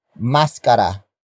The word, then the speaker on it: maskara: It is the focus marker that introduces the main topic of this sentence, and that is maskara. Maskara. Maskara. Maskara is spelled as M-A-S-K-A-R-A.